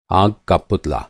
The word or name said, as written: आग का पुतला